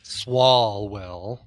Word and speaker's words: Swalwell: Swalwell.